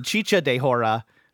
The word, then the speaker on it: chicha de jora: Chicha morada and chicha de jora.